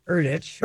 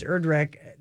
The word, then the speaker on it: Erdrich: Erdich. – Erdich. it's Erdrich.